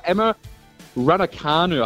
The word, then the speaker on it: Emma Raducanu: So, I know it finished last weekend, but the US Open was won by Emma Raducanu.